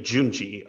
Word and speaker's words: Junji: I hear most people say Junji. – Junji yes.